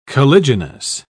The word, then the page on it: caliginous: British English